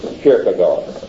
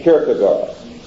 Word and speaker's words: Kierkegaard: The great figure in existentialism is also the great figure in molding neo-orthodoxy, Kierkegaard. K-I-E-R-G-E. K-I-E-R-G-E. No, K-I-E-R-K-E, excuse me, G-A-A-R-D. Kierkegaard, it sounds, but Kierkegaard is pronounced.